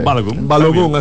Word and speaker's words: Balogun: Y bueno, ya en la delantera, el estadounidense Balogun. ¿Cómo se dice? – Balogun, así mismo.